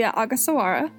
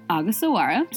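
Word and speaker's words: Ogasawara: That would be the Agasawara. – Agasawara.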